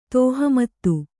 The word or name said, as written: ತೋಹಮತ್ತು